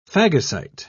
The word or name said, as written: phagocyte